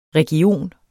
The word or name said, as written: region